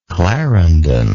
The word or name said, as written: clarendon